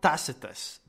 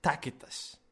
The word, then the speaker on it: Tacitus: It's not Tacitus. Forget Tacitus. Tacitus never existed. It's Tacitus, okay? It's a gripe for people like me, okay?